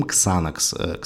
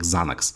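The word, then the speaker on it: Xanax: Потом был валиум, потом ксанакс. Кстати, он в англоязычной среде произносится скорее как занакс.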